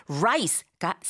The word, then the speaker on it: rice: 이거 rice가 아니고 ra-ra-ra rice라고 합니다. 라라라 라이스라고 잘못 발음하게 되면 그 요즘은 많이 볼 수 없다고 하지만 다행히도 그 이라는 거 있죠? 몸 안에 생기는 작은 벌레 막 간지럽고 막 그렇다면서요. 그 이를 가리키는 단어가 돼요. 그래서 라이스라고 하셔야 쌀이라고 이해하게 되는데 그 쌀은 말이죠.